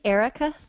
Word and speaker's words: Erika: I'm the only Erika Thornes in the world.